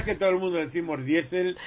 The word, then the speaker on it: Diesel: en alemán es I. Por ejemplo, Kiel, es la ciudad portuaria, es Kiel. Este señor diésel es diésel.